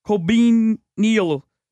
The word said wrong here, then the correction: colonel: Colonel. – Colonel?